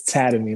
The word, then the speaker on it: Tademy: it's Tademy, like Academy.